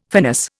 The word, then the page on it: finis: Indian